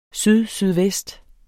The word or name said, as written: sydsydvest